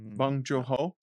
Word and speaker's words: Bong Joon-ho: Memories of a Murder, which is a 2003 film, South Korean film by Bong Joon-ho, something like that. I'm butchering it. – Directed by Bong Joon-ho.